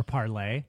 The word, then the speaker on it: Parler: I've so the as most people are probably aware of, there's a new social media app called Parler or Parlay. Mm-hmm. It's pronounced because, you know, French or something.